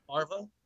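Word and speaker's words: Favreau: We had Sebastian Stan as Bucky Barnes, Paul Bettany as Vision, John, I'm going to butcher this last name, Faravu as Happy Hogan. We had Danai. – John Farva? – There we go. That's how you say it.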